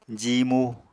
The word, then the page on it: jiimu: apatani